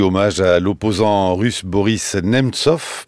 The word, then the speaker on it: Nemtsov: Boris Nemtsov. Je ne sais pas si je mets l'accent tonique comme il faut exactement, Marie Mendras. – Parfait. – Dites-le. Nemtsov.